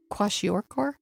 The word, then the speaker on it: kwashiorkor: Kwashiorkor? – That's it. And you pronounced it correctly.